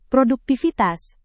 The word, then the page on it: produktivitas: Indonesian